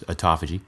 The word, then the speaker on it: autophagy: Um also known as autophagy, but I think the correct, correct pronunciation is autophagy.